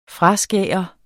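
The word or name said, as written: FRASKÅRET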